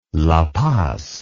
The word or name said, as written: la paz